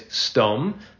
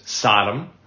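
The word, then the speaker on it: Sdom (סדום): So that translation there is, and the men went on there to Sodom, In Hebrew, we always say it as Sdom, but Americans often pronounce it as Sodom.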